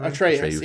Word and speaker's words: Atreus: What he gets called Atreus or whatever. – Atreus. Yeah, I was saying like Atreus.